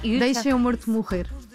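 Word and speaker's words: Deixa o Morto Morrer: It's in Portuguese, it's very hard to pronounce Portuguese, but it's almost. – No, you deixe o morto morrer.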